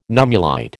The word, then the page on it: nummulite: American English